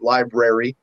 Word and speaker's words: LBRY: It's a very abbreviated but still pronounced library.